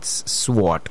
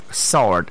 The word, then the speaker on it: sword: And that's when sword, which is not sword, sword. S-W-R-D. S-W-R-D, sword. The warrior was with his sword, not with his sword. No, that is silent there, so it's sword.